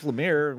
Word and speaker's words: Lemire: Well, Jeff Lemire, which almost rhymes with lima.